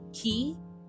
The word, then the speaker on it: Kye: That's G-L-E-N-N. Two N's. Key. It's pronounced like a key you put in a door, but it's spelled K-Y-E.